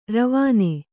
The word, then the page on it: ರವಣ: ravaṇa